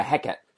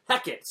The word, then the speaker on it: Hecate: And how do you say the name again? Hecate? – Yeah, Hecate. – Hecate.